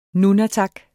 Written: nunatak

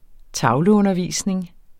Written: tavleundervisning

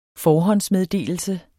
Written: forhåndsmeddelelse

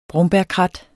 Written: brombærkrat

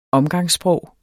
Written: omgangssprog

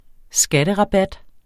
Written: skatterabat

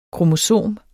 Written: kromosom

